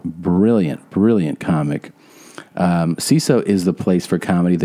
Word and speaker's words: Seeso: CISO. It's spelled S-E-E-S-O.